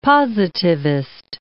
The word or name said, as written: positivist